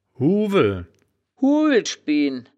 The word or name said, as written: huuweln